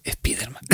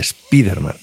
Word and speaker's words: Spiderman: Y en los cómics dicen Spiderman con acento de español o de Madrid. – Con acento en la I, Spiderman. – Bueno, con acento de Madrid, además, has dicho. – Es Drújula. Es Drújula.